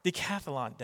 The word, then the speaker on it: decathlon: Decathlon, DECA.